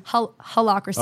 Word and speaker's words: Holacracy: Holacracy. – Oh, it's like hell. – It's spelled, well, no. – It's Holacracy in here. – It's spelled like Ola.